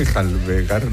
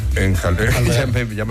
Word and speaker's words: enjalbegar: Para que volviera a jabelgar, ¿cómo se dice? – Enjalbegar la pared. – O enjabelgar. – Enjalbegar.